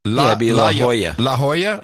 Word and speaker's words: La Jolla: Now, how would I pronounce this? Yahola Salt Corporation? J-O-L-L-A? La Jolla. La Jolla Salt Corporation. La Jolla.